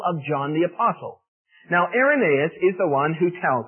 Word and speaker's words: Irenaeus: And his name is Irenaeus, and that word is spelled I-R-E-N-A-E-U-S. It's pronounced Irenaeus.